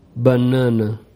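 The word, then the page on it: banana: Brazilian Portuguese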